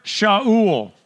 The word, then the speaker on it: Shaul: So Ananias went away and went into the house, and laying his hands on him, he said, Brother Shaul, which is what it, how it would really be pronounced,